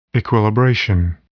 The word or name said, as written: equilibration